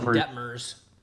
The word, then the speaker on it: Detmers: Detmers? – I've always said Detmers.